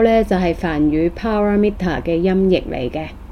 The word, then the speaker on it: paramita: paramita。 – 咁喺 A 字上面有一畫嘅咧，就係、是、應該要發音長少少嘅，咁所以 Google 嗰個讀音咧應該係比較正確。咁呢一個 paramita 咧，咁就係四個音節啦，咁所以佢係譯做波羅蜜多啦，咁係一個音譯啦，好明顯。